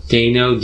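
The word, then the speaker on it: Deino: I said Dino. – Dino? – But I don't know. It's D-E-I-N-O. So Dino, I don't know.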